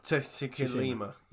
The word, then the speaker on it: Tsushima: It's either Tsushima or Shishima. – Tsushima.